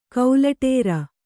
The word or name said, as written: ಕೌಲಟೇರ